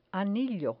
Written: anillo

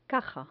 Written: caja

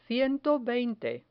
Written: ciento veinte

